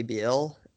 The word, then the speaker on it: Beal: It rhymes with heel. – The problem with them trading for Bradley Beal is that they would 100% have to throw in, not throw in, he'd be the centerpiece of the deal.